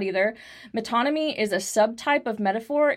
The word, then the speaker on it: metonymy: I don't know how to pronounce this word, and it looks like it's metonymy? – Metonymy. – Metonymy. – Yes, you're right.